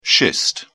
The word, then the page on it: schist: British English